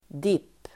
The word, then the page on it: dipp: dip: